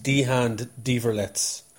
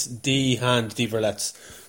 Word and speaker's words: Die Hand die verletzt: And I think it's Die Hand Die Verletzt.